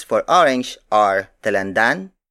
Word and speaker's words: dalandan: Another native color is Talan Dalandan.